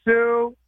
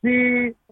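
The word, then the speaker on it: Siu: It's spelled S-I, many, many U's. I've been told it's Sue. Some people come up and tell me it's a Spanish word. It's pronounced C. But I'm like, everyone's saying Sue. I'm not Portuguese. I'm only Portuguese today. And they're saying Sue.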